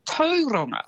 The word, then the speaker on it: Tauranga: which is, I think, a lovely way to say it, and tauranga as well as actually tauranga.